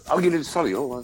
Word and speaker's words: Solihull: I don't even pronounce the H. I say Solihull.